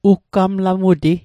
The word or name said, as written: uggamlamuti